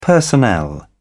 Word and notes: personnel: British English

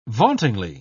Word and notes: vauntingly: British English

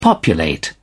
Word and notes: populate: British English

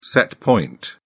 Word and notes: set point: British English

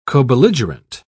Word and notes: cobelligerent: British English

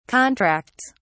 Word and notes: contracts: British English

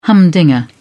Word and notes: humdinger: British English

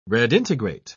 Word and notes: redintegrate: British English

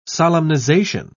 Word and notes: solemnization: British English

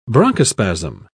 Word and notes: bronchospasm: British English

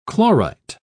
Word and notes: chlorite: British English

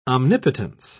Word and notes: omnipotence: British English

